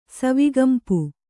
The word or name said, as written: ಸವಿಗಂಪು